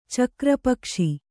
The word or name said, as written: ಚಕ್ರಪಕ್ಷಿ